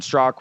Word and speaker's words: Straka: It's at Brandon Strock, which is spelled S-T-R-A-K-A. There's a very peculiar A at the end of my last name that nobody says.